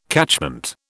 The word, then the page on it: catchment: British English